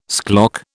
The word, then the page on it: schlock: British English